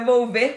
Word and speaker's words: Volver: Ah, então Muito bom, mas é Volver com L.